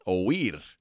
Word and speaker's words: oír: So caso, and that is spelled C-A-S-O, caso. Muy bien. Now, the verb of the day today is oír, and oír means to hear.